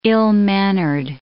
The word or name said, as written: ill-mannered